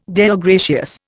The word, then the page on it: Deo gratias: Indian